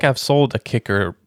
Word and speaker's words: KIKK: And it says kicker.